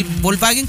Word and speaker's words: Volkswagen: La forma correcta de pronunciarlo para que aprendan en casa del Volkswagen, como le dicen, es Volkswagen. Vale.